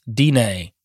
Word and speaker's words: Diné: Cheers from the Navajo, dying pronounced Diné Nation.